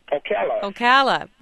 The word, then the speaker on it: Ocala: Ocala. – Welcome to Talk of the Nation. – Ocala, Florida.